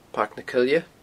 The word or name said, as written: Pairc na Coille